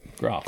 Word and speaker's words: Groff: G-R-O-F-F. – Groff.